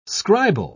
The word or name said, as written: scribal